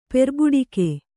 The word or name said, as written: ಪೆರ್ಬಡಿಕೆ